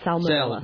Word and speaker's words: salmonella: It's not pronounced salmon. It's salmonella. – Salmonella. – Yeah, but the word, but fish isn't pronounced salmon. It's pronounced salmon. Salmon.